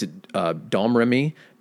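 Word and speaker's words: Domrémy: So she was born in 1412 in a place called, the way they pronounce it, it's Domremy, but it sounds like Do-Re-Mi.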